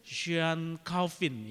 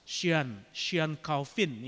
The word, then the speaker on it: Jean Calvin: John Calvin ini nama aslinya ya di dalam bahasa Prancis itu Jean Calvin ya. Itu kalau bacanya harus monyong sedikit mulutnya begitu ya, agak susah. Jean Calvin ya, lahir pada 10 Juli 1509 di kota Noyon, Picardy, Prancis.